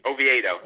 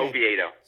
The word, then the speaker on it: Oviedo: Oviedo. – Oviedo. Oviedo.